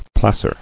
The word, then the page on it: Placer: plac·er